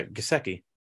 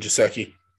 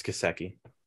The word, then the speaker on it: Gesicki: Oh, that's how you say his name? – Yeah, Gesicki. – Oh, I always said Gesicki. – Nah, it's Gesicki.